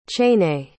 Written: chaîné

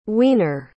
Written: Weiner

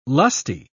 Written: lusty

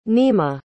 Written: nimah

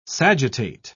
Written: sagittate

